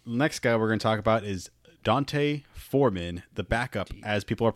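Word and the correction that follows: D'Onta Foreman: Did I say it wrong? Deontay Foreman.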